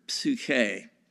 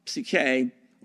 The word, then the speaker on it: psyche: a transliteration of the Greek word psyche. Psyche, or psyche, as we'd like to pronounce it in English, psyche, is the Greek word for, the regular translation would be soul.